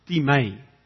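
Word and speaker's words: time (τιμή): The word honor in the Greek is the word time, or we pronounce it time.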